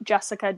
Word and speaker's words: Jessyca: So you can follow me on Twitter at Jessyca Defense.